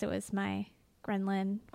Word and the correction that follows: ghrelin: How do I say it? – Ghrelin.